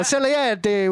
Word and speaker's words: Salieri: Salieri with the gabagool over here.